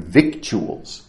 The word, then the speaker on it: victuals: Victuals, V-I-C-T-U-A-L-S. It's written victuals, but it's pronounced victuals, and it means food.